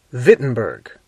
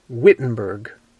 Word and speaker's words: Wittenberg: They pronounce their W's as V's, so they wouldn't have said Wittenberg, they would have said Wittenberg.